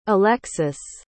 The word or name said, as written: alexis